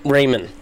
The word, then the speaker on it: Raymond: Raymond. – Just like it sounds, or just like you would. It's spelled Raymond.